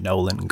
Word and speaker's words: Nolen: I think it's Nolan, but it's spelled N-O-L-E-N. Nolan Gertz is the author of this book. – Nolan. G-G-U-R? – G-E-R-T-Z.